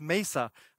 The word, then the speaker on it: Mesa: It's Mesa, right? I thought it was, what do I know? I said Mesa, and Dan's like, no, it's Mesa. I have now learned how to say it right, I think.